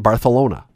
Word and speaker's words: Barcelona: We have fights around my house all the time because they think I'm making this up, but the Spanish actually call it Barcelona. – That is the correct pronunciation.